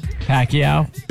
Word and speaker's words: Pacquiao: How are you supposed to say his name? – Pacquiao. – Pacquiao? I don't know. – Manny Pacquiao. – You say. I like how you like, do it, like, right? – It's like Okinawa, you know? – Okinawa.